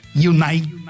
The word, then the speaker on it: United: Hay uno que dice que pronuncia Manchester United. – United. – Con las dos y latina.